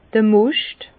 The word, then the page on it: d'r Moscht: Bas-Rhin; Herrlisheim